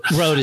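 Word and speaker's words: Rode: Road is how, um, how for, pronounce it.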